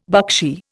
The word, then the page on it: buckshee: Indian